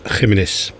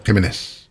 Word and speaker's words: Jiménez: Flaco Jiménez. Zo moet je het uitspreken, hè, geloof ik. – Ja, Jiménez. – Jiménez.